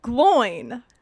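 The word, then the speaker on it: Glóin: Oh, so you're saying like glowing, but with kind of like a Scottish accent. So it's yes. Gloin. – Yes, it's G-L-O-I-N. There's an accent on the O.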